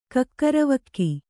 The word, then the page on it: ಕಕ್ಕರವಕ್ಕಿ: kannada